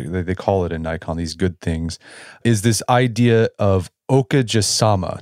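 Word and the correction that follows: okage-sama (おかげさま): Did I say that right? – You're close. It's okage-sama. – What is that, and how can it guide our Thanksgiving reflections?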